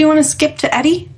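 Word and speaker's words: ED-E: And I am here with a dead Edie. See? – So you pronounce it Edie? – Eddie, sorry.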